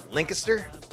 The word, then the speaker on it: Lancaster: But I do know that the city, we have a city in California, we call it Lancaster. But you guys have a city spelled the same way, and it's called Lancaster? – Lancaster, yep.